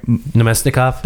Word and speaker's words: Namestnikov: Namestnikov.